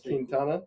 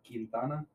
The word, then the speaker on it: Quintana: Quintana. – Quintana? – Quintana. – Like katana, you know, Quintana.